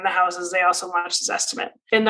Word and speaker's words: Zestimate: You mentioned earlier about the Zestimate. Did I pronounce it right? – Yes.